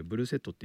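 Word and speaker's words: Bluesette: で、アルバムの方のカタカナ表記はブルースエットって書いてあるんですけど、他のあの解説書を読むとブルーセットっていうね、まあ多分発音だともうリエゾンでブルーセットっていうのでしょう。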